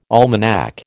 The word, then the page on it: almanac: American English